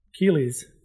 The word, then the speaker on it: Keighley: Jeff Keighley's. Keighley's? Knightley's? How do you pronounce his last name? – Keighley. – Keighley? – Yeah.